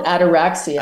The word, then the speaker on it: ataraxia: And I was wondering what your understanding, I've heard you mention, ataxia? It's like a sort of serenity. Yeah. How do you pronounce it? Sorry. – It's called ataraxia. – Ataraxia. – I never really that's it.